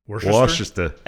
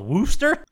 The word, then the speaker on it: Worcester: Well, it's like, you know how that town around Boston is Westchester? – Worcester. – And it's called Wooster? – Yeah.